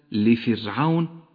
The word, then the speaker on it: لفرعون: أما الراء في كلمة لفرعون فهي راء مرققة لأنها سكنت وجاء قبلها حرف مكسور